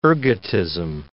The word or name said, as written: ergotism